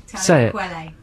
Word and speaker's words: Talib Kweli: Talib Kweli.